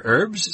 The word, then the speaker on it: herb: The British say herbs and Americans mostly say herbs without the H.